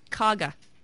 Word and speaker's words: Cuyahoga: You can spell the words Cuyahoga and Tuscaroras. – But you can't always pronounce them. – It's Kaga. – It's Kaya. – I grew up in Kaga Falls.